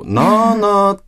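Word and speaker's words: ナーナー: なーなーって、はい、あ、ごめんなさい、イントネーション大体合ってますか、これ。 – 合ってます、合ってます。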